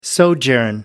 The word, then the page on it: sojourn: American English